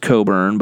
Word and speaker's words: Cockburn: Ayo Dosunmu and Kofi Coburn, it is pronounced Coburn, but it is spelled Cockburn, children.